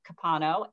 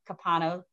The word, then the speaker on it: Capano: So that's K-R-I-S-T-I-N-A. And I have two last names, Lentz Capano, L-E-N-T as in Tom, Z as in Zebra, Capano, C-A-P-A-N-O.